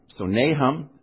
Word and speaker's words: Nahum: Nay, the sound of a pony. Hum, what you do when you don't know the words. So Nahum, I'm sure that's not the way it would be pronounced if we were to go over there. Probably quite a bit different. But nevertheless, it's unique among the Hebrew prophetic literature.